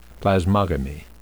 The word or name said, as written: plasmogamy